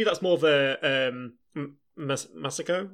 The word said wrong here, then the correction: Masaru: I never say his name. Masaru, sorry. – Masaru. – I never say his name out loud.